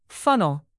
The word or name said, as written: funnel